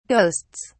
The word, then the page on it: ghosts: British English